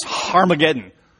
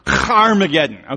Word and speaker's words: Harmageddon: And it's literally, and some of your translations say this, it's literally pronounced Harmageddon. Like, you got to get that phlegm, that Hebrew phlegm thing going. Harmageddon, okay? It's Harmageddon. And we drop that H because it's annoying to us in English.